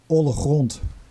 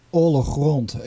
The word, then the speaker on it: Ollegrond: I would say Ollegrond. – Ollegrond? – Ollegrond, yeah. – Grond, yeah. – Yeah, obviously it's this part of the Netherlands has the harsh G, which the whole world probably can't pronounce without getting a sore throat.